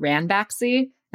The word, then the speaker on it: Ranbaxy: So probably the most high profile case of this was the story about Ranbaxy. Am I pronouncing that right? – Yes, you are.